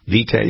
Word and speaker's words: vitae: curriculum vitae, isn't that how they pronounce it? Curriculum vitae? Vitae? Yeah, no, it's vitae.